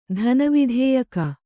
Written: ಧನವಿಧೇಯಕ

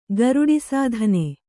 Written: ಗರುಡಿಸಾಧನೆ

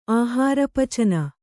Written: ಆಹಾರಪಚನ

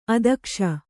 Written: ಅದಕ್ಷೆ

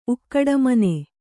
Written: ಉಕ್ಕಡಮನೆ

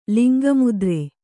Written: ಲಿಂಗಮುದ್ರೆ

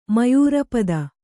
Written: ಮಯೂರಪದ

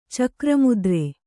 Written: ಚಕ್ರಮುದ್ರೆ

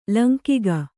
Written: ಲಂಗಿಗ

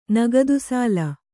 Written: ನಗದುಸಾಲ

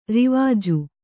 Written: ರಿವಾಜು

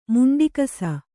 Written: ಮುಂಡಿಕಸ

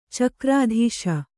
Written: ಚಕ್ರಾಧೀಶ

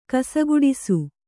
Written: ಕಸಗೂಡಿಸು

